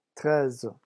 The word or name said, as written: treize